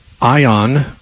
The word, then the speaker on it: aion: In the New Testament, it's generally either aion, which is, we'd spell it A-I-O-N, or it's aionios, which is the same word made into a preposition.